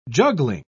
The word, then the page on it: juggling: British English